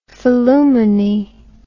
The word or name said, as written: phillumeny